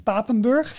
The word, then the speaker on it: Papenburg: Voor onze donateurs, als exclusief uitje, heb je de mogelijkheid om met ons mee te gaan naar de werft in Papenburg. Zeg ik het goed, uh, uh Helemaal goed. Ja, oké.